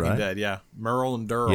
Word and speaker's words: Merle: So, Merle Dandridge, or Merle, is it Merle? How do you say Merle. – His name, director? – Merle.